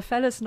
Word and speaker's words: Felice: Äh, ich buchstabiere mal Fellas noch, weil ich glaube, die Aussprache nichts über, wie man es schreibt, aussagt. – Also Gerne. Also man schreibt F-E-L-I-C-E.